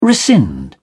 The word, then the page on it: rescind: British English